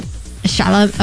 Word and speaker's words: Chalamet: Chalamet. Oh, sorry. How do you say it? Chalamet.